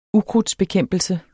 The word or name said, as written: ukrudtsbekæmpelse